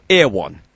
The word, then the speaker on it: Erewhon: 咁叫 Airwan。 Airwan 好得意喎佢其实就是 nowhere 呢个字倒转嚟穿。不过呢佢就有少少改变因为佢要就个发音呢就係、是、 E-R-E-W-H-O-N。咁如果你倒转嚟串呢就差唔多即係 nowhere 咁。